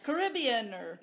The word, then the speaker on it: carabiner: So it comes in a bag, has a little carabiner. I always feel like I'm going to butcher that. – Caribbeaner. – Yes, that's exactly.